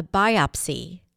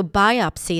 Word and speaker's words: biopsy: The doctor ordered a biopsy to determine whether the tumor was cancerous. So biopsy is another important word to know. The stress is on the first syllable. Biopsy. Biopsy.